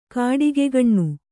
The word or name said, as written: ಗಿಡಗಕಣ್ಣು